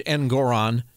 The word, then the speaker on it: Engoron: This guy, Judge Engoron, which rhymes with moron.